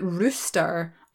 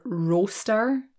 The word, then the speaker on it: roaster: So I think somebody American asked me, is it rooster or roaster? And I didn't think those two things sounded that similar. So it's roaster, as in that rhymes with toast.